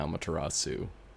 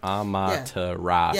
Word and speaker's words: Amaterasu: How do you say it? – Amaterasu? – That's how. Yeah, Amaterasu. Dr. Saturn says Amaterasu. – Yeah. Yeah, Dr. Saturn's right.